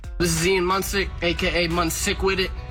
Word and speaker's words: Munsick: I keep mispronouncing Ian Musnick's name. Mm-hmm. Moonsix. Mm-hmm. What am I saying? – You want to hear it from the man himself? – I do. Let me hear it. – Okay, and he's going to give you a little hint on how to pronounce it as well. – This is Ian Musnick, a.k.a. Munsick with it.